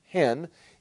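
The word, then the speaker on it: hen (ἕν): And then 12 times the word one, or E-N, with a rough breathing march, which is pronounced hen.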